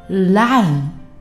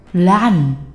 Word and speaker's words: Latin: Latin, Latin，咦，Latin，这里面的 t 哪去了呢？明明有 t 啊！